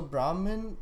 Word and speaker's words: Brahmin: Is there like any, because I know that the Brahmin, am I pronouncing that correctly? – Mm-hmm. – That's kind of like a religion in India, correct? – So it's not a religion. That's how, so Brahmin.